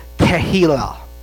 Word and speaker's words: Tehillah: And this word here that you find in Isaiah 61 is Tehillah. I'm not 100% sure if I'm saying that right, but it is Tehillah. That's the way they pronounced it out. Tehillah.